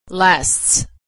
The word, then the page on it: lasts: s